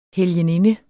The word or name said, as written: helgeninde